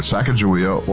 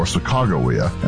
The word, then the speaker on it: Sacagawea: In Lewis and Clark, you have to choose either Sacagawea or Sacagawea, and out of respect for the Shoshone, Mr. Furman goes with their preferred pronunciation.